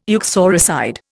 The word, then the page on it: uxoricide: Indian